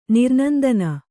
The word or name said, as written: ನಿರ್ನಂದನ